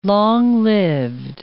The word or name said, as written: long-lived